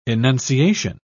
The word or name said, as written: enunciation